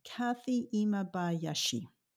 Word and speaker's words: Kathy Imabayashi: Kathy Imabayashi.